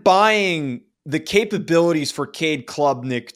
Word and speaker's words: Klubnik: I think Clemson is, again, a very good team in 2023, but I am selling the Heisman hype for Cade Klubnik in 2023. – Hell of a pronunciation there, by the way. That's probably one of the worst names to pronounce. – That's how you pronounce it.